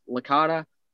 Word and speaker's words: Licata: It's spelled A-C-C-U-R-S-I-O. And then my last name is Licata, L-I-C-A-T-A.